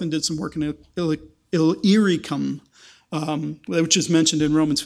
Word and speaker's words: Illyricum: Illyricum, which doesn't get mentioned.